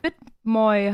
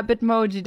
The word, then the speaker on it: Bitmoji: Heißt es Moi? Habe ich es falsch gesagt? – Bitmoi heißt es, glaube ich. – Ich glaube, es heißt Bitmoji. – Echt? – Oh. Hätte ich schon gedacht, oder? – Oh, sorry, ja, Bitmoji dann.